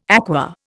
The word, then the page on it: aqua: Indian